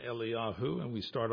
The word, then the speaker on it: Eliyahu: So, and that is pronounced Eliyahu.